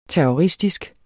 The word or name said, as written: terroristisk